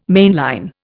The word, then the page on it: mainline: Indian